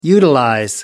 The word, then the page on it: utilize: American English